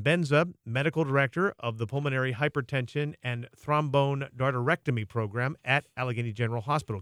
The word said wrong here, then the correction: thromboendarterectomy: Well, go ahead and say thromboendarterectomy.